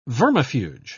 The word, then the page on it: vermifuge: British English